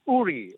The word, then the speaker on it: Uri: It's Uri. – Uri. – It's Uri. It's actually a place in, as they POK, as they call it, Pakistan Occupied Kashmir. That's what Uri is all about.